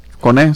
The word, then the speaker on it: Coné: Ajá, de Canadá, Connie, ¿no? – Coné, sí. Coné. Es que tiene acento en la E.